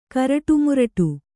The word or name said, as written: ಕರಟುಮುರಟು